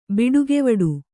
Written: ಬಿಡುಗೆವಡು

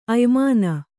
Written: ಅಯ್ಮಾನ

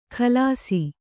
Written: ಕಲಾಸಿ

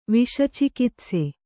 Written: ವಿಷಚಿಕಿತ್ಸೆ

